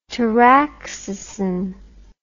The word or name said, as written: taraxacin